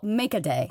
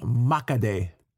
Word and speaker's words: Makedeh: It's called Makaday. – Makaday. – Makaday. – Well, let's spell it. It's M-A-K... – A-H... – No, M-A-K-E-D-E-H. Makaday.